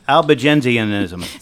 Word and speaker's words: Albigensianism: Albigensianism.